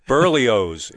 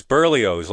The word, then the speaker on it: Berlioz: Berlioz. It's Berlioz, like the breakfast cereal.